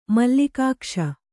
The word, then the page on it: ಮಲ್ಲಿಕಾಕ್ಷ: mallikākṣa